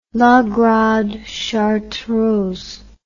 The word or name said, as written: grande chartreuse la